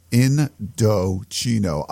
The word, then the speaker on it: Indochino: So it's pronounced Indochino, Indochino.